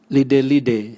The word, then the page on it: lidelide: Raga